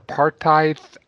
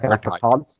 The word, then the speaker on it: apartheid: It's actually like apartheid. an Afrikaner word. – Right. – So apartheid, I believe, is the is how you would pronounce it, with like the Dutch influence or the Afrikaner influence.